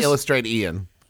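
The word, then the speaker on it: Illustratian: And I think it's Illustrate Ian. – Illustrate Ian. Well, it's spelled like illustration, but with an A at the end instead of an O.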